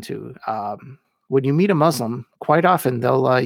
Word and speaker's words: Muslim: Throughout this podcast, I have said the word Muslim. This is actually an incorrect pronunciation. But the correct pronunciation just sounds forced and almost snobbish from a Christian English speaker. So I will continue to say it wrong. If you want to pronounce Muslim as it appears in the Arabic, it should be Muslim. It should start with the sound of moose, not muzzle. The most important part is using the S and not a Z.